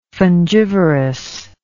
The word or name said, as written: fungivorous